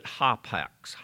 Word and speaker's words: hapax: Once, the word once, you pronounce it hapax, hapax, H-A-P-A-X, hapax, hapex.